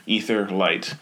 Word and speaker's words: Aetherlight: If you just Google the Etherlight, and it's spelled a little funny, so it's AETHERLIGHT, Etherlight.